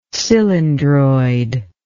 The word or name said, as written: cylindroid